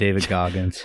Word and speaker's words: David Goggins: David Goggins. – Yeah, David Goggins.